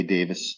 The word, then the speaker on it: Davies: You know, I read that you were talking about how Ray Davis, by the way, for those of you who may wonder, are we mispronouncing the last name of Ray and Dave? Because their last name is spelled D-A-V-I-E-S. So wouldn't it be pronounced Davies? But no. Oaken has his red pen up right now with alarm. – I said Davies, but I forgot it is pronounced Davis.